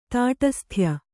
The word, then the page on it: ತಾಟಸ್ಥ್ಯ: tāṭasthya